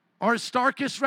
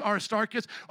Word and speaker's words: Aristarchus: Aristarchus, rather, I mispronounced his name, it's Aristarchus.